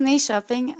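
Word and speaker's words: Nyköping: Não sei se é assim que se pronuncia, é? – É, chama-se Ni Shopping.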